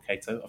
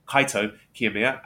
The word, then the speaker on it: Kaito Kiyomiya: Not Kaito, I've said it wrong. Kaito Kiyomiya and Ren Narita.